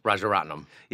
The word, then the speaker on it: Rajaratnam: Which is I never know how to say his last name, Raj. – Rajaratnam.